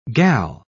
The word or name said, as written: gal